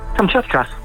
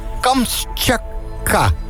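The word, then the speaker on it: Kamtsjatka: Hoe zeg je het nou? – Kamtschatka. – Kamtschatka. – Ja. – Oh ja, maar er staat er ook nog een T bij. Kamtschatka. Oh ja, Kamtschatka. Dat is hem.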